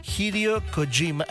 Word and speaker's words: Hideo Kojima: el nuevo videojuego de Hideo Kojima.